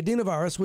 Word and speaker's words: adenovirus: Research is continuing, but experts believe that the hepatitis spike is linked to the adenovirus. I said it wrong. The adenovirus, which is like the common cold.